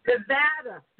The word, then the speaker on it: Nevada: I can't say it right. You say it right. – Nevada. – Nevada.